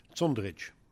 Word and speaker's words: Zundrich: Zundrich. It's a little bit harder on the Z.